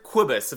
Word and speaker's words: Cobus: first name is Cobus. If you think about, like, Quidditch.